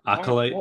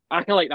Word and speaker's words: Acolyte: Um, we got Star Wars Ac- Acu- Aculite. Is that how you say it? – Acolyte. What? – Acolyte. That's it. Acolyte.